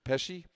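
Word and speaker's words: Pesce: Or how do I say it right? Pesci.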